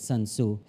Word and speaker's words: Sun Tzu: The book, The Art of War by Sun Tzu, that's how you say it, Sun Tzu, has been a game plan to succeed for the last 800 plus years.